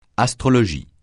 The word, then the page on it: astrologie: francais